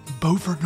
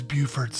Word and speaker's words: Beaufort: But first, we talk about where she lives, Beaufort, North Carolina, which looks the same, but is not pronounced the same, as Beaufort, South Carolina.